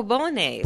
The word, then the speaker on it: Bolognese: La Bogna or Bolognese. – Oh, Bolognese. – Bolognese. – Yeah. Mm-hmm. – There you go. – You say it much better than I do. – Oh, thank you. – Bolognese.